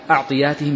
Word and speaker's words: أعطياتهم: أعطياتهم بفتح الهمز، وهو الصواب، إذ ربما ذهب وهلي فنطقتها بالضم، فنبهني بعض الأخوة، أخونا سعد جزاه الله خير، إلى أنها بالفتح،